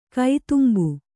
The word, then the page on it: ಕದಂಬ: kannada